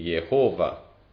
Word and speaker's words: Yahvé: L'opinione pubblica generale fu che il nome dovesse essere pronunciato Yahvé, ma ad un certo punto si presentò la pronuncia di Jehovah e questa divenne sempre più popolare. Ma Yahvé è probabilmente la pronuncia corretta, sebbene non lo sappiamo per certo.